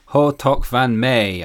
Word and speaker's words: Hou toch van mij: That was Bob Benny with Hortok van May. I said it wrongly before. I know how to say it now.